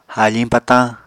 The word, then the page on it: haafiñ patañ: Apatani